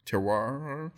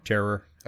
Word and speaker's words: terroir: Terroir? – Terror.